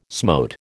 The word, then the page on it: smote: American English